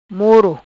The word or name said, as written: Moro